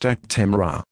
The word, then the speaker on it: Actemra: This is the consumer medicine information for Actemra, pronounced Actemra.